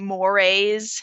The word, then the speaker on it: mores: And actually, D is pronounced, it's kind of funny, it's pronounced like mores.